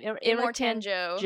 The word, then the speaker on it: Immortan Joe: Immortan Joe.